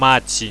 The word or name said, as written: maci